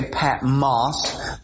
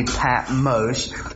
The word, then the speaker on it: Patmos: Now, some people say Patmos. That's uh, uh, but the original language is a long O, so it would be Patmos.